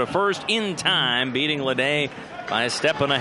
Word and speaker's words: Lada: Lede, again spelled L-A-D-A, but pronounced Lede, out of William Carey University.